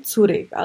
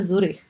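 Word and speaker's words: Zürich: Oproti tomu teda, který město ve Švýcarsku je největší – je to Curych, který ale se nevyslovuje tady Curych, ale vyslovuje se Zurych. Samozřejmě to jako vychází z nějaký Němčiny,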